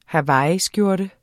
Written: hawaiiskjorte

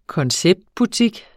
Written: konceptbutik

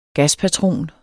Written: gaspatron

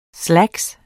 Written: slacks

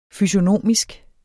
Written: fysiognomisk

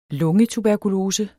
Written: LUNGETUBERKULOSES

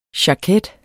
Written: jaket

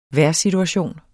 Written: vejrsituation

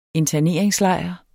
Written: interneringslejr

